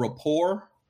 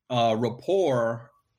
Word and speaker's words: Rapport: So my very first podcast that I created was it's spelled Rapport, R-A-P-P-O-R-T, but it's pronounced The Minority Report.